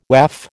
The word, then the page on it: WAF: American English